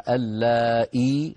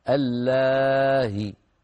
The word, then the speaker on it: اللائي: وقلت بأن التسهيل للهمزة أن تنطق الهمزة بين الهمزة المحققة وبين الحركة التي هي منها، فهنا الهمزة مكسورة اللائي، فتسهل بين الهمزة المحققة وبين الياء ويكون نطقها هكذا اللاهي.